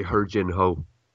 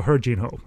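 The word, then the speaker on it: Heo Jin-ho: H-Y-E, how do you pronounce that? – It's actually Her Jin-ho. It's H-U-R. – So Her Jin-ho, essentially. – Yeah.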